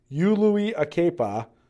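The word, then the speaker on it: Uluilakepa: You just say every vowel. U-Lui-Akepa. Okay, so there's your pronunciation guide. U-Lui-Akepa.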